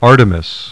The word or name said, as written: Artemis